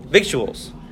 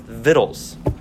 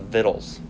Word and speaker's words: victuals: A lot of people pronounce this word, like you said, as victuals. It is actually pronounced vittles. I don't know why, but if you look up the pronunciation, it's called vittles.